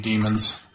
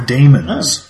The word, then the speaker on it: daemon: They're daemons. Yeah. They're not demons. They're... – Sorry. – Daemons. – Like that, daemons. – Yes. – Oh, God. Well, I imagine it actually is pronounced demon, isn't it? – I guess, yeah. – Except that you have to say it daemon to make the distinction, really.